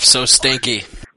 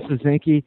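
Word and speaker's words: Soszynski: I'll go with Christoph So Stinky. – Christoph, we'll give you the pronunciation. Christoph Sosinki, UFC 131, that is correct.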